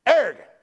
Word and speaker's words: Aragon: You know, they don't ever say it right on the news. Aragon, it's Aragon. Arrogant.